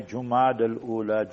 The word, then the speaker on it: جمادی الاولی: تو اس مہینے کے نام ہے جمادی الاولی جیم پر ضمہ ہے جیم پر پیش